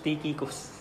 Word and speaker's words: Tychicus: It's pronounced Tychicus.